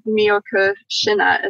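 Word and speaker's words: Miyoko Schinner: I was listening to your one with Miyoko Shina.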